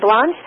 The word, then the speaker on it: Blanche: That's spelled J A C Q U E L I N E, and Blanche is B-L-A-N-C-H-E. It's like the French blanche, but right, Jacqueline Blanche.